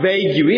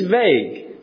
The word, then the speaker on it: vague: Whenever you see a word ending with a U-E, the U-E is silent. So it's not vague-y, it's vague. It's not tongue-y, it's tongue."